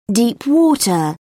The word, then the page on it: deep-water: British English